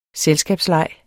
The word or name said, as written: selskabsleg